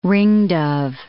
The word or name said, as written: ringdove